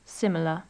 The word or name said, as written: similar